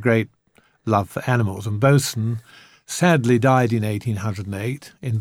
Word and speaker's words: Boatswain: He had a dog called Boson, spelt boatswain, but pronounced Boson.